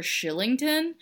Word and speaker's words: Kylington: It's spelled Kylington. – Yes, but it's pronounced Shillington. He's a Swede. I don't know if that's how Swedish works, but that's how you say his name.